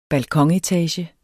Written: balkonetage

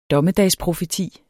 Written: dommedagsprofeti

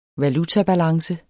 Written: valutabalance

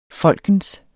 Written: folkens

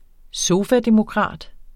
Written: sofademokrat

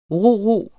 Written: ro-ro

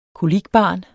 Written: kolikbarn

